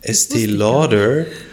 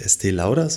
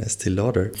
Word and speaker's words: Estée Lauder: Und da habe ich gelernt, Estée Lauder, weil es ein New Yorker Unternehmen ist. Mhm. Und deshalb spricht man nicht Estée Lauder, sondern Estée Lauder.